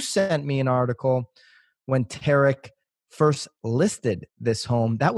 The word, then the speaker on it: Tarek: We have a flip or flop star, Tariq Tarek. – Tarek. Say it's spelled like Tariq.